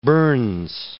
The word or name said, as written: burns